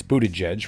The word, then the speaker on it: Buttigieg: that's Buttigieg for those of you who want to mispronounce his name the way he mispronounces it,